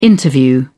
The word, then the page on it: interview: British English